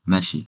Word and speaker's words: 飯: First of all, the kanji, meshi, has two ways to read. And kun-yomi is meshi, and on-yomi is han.